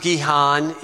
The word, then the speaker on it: Gihon: We pronounce it Gihon in English.